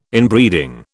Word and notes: Inbreeding: British English

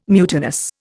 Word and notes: mutinous: Indian

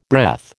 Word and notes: breath: American English

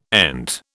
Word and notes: ENT: British English

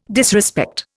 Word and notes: disrespect: Indian

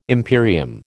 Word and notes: imperium: American English

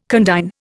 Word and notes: condign: Indian

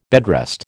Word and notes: bedrest: American English

